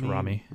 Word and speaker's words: Rami: It's Rami.